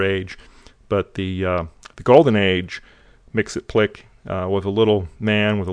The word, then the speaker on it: Mxyzptlk: It's Mxyzptlk, pronounced Mxyzptlk.